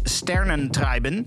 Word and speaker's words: Sternentreiben: Die, die maakte de remix van Claudio Ricci's Sternentreiben. Ja, dan moet je op zijn Duits uitspreken ook.